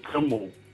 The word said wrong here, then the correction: Kumble: Kimball. I can't pronounce his name properly. – Kumble. Anil Kumble.